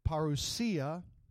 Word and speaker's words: parousia: parousia, or if we pronounce it the way they pronounce it in Greek, parousia.